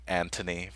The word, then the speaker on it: Anthony: She said, this is for Red Dragon, by the way, and this was when we were discussing Antony versus Anthony Hopkins, Anthony Hopkins. – Yes. – Says, hey guys, the Anthony pronunciation is a British thing, much like Rafe for Ralph.